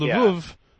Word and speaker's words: Lwów: Back when Lwów, I know the W is pronounced in Polish like a V, right? Or something like that. But, when Lwów